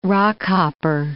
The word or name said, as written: rockhopper